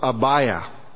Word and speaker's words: Abijah: And we learned that Zacharias was of the course of Abijah. The Old Testament, the spelling is a little different. It has a J in it, pronounced the same, Abijah.